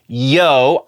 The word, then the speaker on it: Yeoh: I was saying Michelle O, or at least it sounded like I was saying Michelle O. It is not O, it's Yo, I'm a dummy and I apologize.